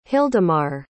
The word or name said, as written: hildimar